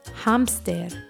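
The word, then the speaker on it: hámster: And remember, even though this is a cognate and it's spelled the same way, it's pronounced a little differently. So for hamster, we say hamster.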